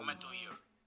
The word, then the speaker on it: Memento Mori: Yeah, Memento Mori.